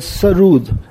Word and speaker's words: sarod: Sarod, actual pronunciation was sarood.